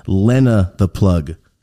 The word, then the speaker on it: Lena the Plug: This is Lena the Plug.